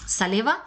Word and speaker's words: Salewa: encontré en esta marca eh, Saleva, se escribe Salewa con W, es una marca alemana.